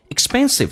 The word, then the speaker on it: expensive: Expensive 在美式发音，那个 k 是不用发音，因为两个辅音只用发后面一个，而且 sp 发成 sb，叫做 expensive。记住 k 不发音，但气流阻塞一下。